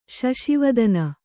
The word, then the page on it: ಶಶಿವದನ: kannada